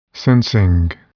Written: sensing

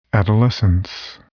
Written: adolescence

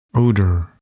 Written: odor

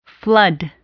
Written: flood

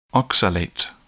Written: oxalate

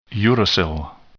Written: uracil

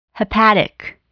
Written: hepatic

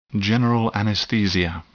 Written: general anesthesia

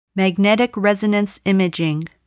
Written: magnetic resonance imaging

